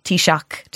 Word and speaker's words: Taoiseach: You don't pronounce the CH at the end. – Oh, I do. Yeah. – Because Taoiseach. I say Taoiseach, but in Donegal, they say Taoiseach. – Oh, yeah. That's nice, too. But I mean, as long as you're not saying k, you know.